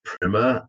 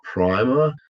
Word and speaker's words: Primer: And yeah, the film is pronounced Primer and not Primer. So Primer is in like a first lesson on something. I guess we can call it Primer if you want, but the word in the English language is Primer.